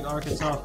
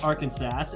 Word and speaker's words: Arkansas: Shout out Arkansas, big Arkansas fan. It's not pronounced Arkansas. It's pronounced Arkansas out of legislation.